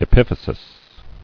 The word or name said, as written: Epiphysial